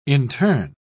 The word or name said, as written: intern